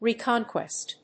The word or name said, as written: reconquest